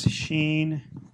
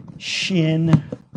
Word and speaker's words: shin: So the letter Sheen, you'll hear pronounced like this, Sheen. Sometimes you'll hear it pronounced like this, Shin. Same thing. It's just the way they're pronouncing the I as an E or an I.